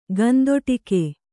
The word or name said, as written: ಗಂದೊಟಿಕೆ